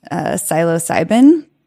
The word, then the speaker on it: psilocybin: psilocybin, silent P, if anyone's wondering, to develop a new model for drug-assisted psychotherapy.